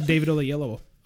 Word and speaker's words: Oyelowo: That was David Oyelowo. – How do you say that? – David Old Yeller. – David Oyelowo. – David Oyelowo. – Oyelowo.